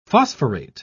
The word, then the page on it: phosphorate: British English